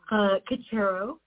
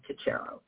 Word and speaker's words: Kachero: Kachero, I think you were doing a pretty good job. It's one of those Ellis Island butchered names from Italy that didn't start like Kachero at all, but we've landed on Kachero.